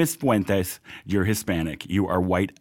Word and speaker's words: Fuentes: Kanye West went on the Alex Jones show with, how are we pronouncing his last name? – Fuentes. – Fuentes.